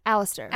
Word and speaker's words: Alistair: No, that sounds Alistair. Like Hollister. Alistair.